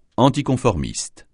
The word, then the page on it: anticonformiste: francais